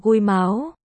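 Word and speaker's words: gui máu (龜毛): Nó được phát âm là gui máu.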